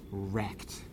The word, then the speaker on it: RECD: I mean, this is what Noam Chomsky mockingly calls really existing capitalist democracy, acronymized, the acronym being RECD, pronounced as wrecked.